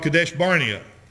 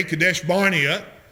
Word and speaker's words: Kadesh Barnea: Moses led them over to a place called Kadesh Barnea. Now some people pronounce it differently. That's my pronunciation. I'm sticking with it. But anyway, Kadesh Barnea, they went from there over into the land of Canaan.